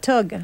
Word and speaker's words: thug: Oh, thug. – Thug, c'est en verlan? – Oui, thug, verlan? Mais prononcé comme en français.